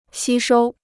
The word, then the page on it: 吸收: xī shōu